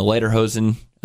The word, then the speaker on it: Lederhosen: If you can find it, it's got the guy Justin the Lederhosen. Did I pronounce that right? – Mm-hmm. Yes.